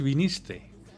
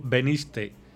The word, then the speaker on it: viniste: Y os puedo adelantar que según los resultados de la encuesta realizada por Spinvoz, la palabra peor pronunciada es viniste. Dicha palabra suele pronunciarse como veniste, ya que la mayoría de las formas y tiempos verbales del verbo venir comienzan por B.